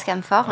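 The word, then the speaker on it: Cam4: sur Cam 4, ça se lit Cam 4, mais ça se prononce Camfort, en ouais.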